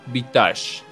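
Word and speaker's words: viatge: Y se pronuncia vitage.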